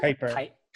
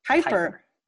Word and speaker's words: Kuiper: Am I saying that right? Kuiper? – Kuiper. – Kuiper.